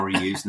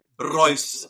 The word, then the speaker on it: Reus: How now he just will not call him Royce. He's now Reus. No yeah. So Reus, I'll preface how I say it with that. I'm American and I do my best pronouncing. – Yeah, but you know you ought to say Royce.